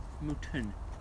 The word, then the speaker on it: Milton: And it was like, Milton, the T. Pronounce it properly.